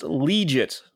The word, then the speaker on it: Liuget: Corey Legit, pronounced Legit, like legit, but it's spelled L-I-U-G-E-T.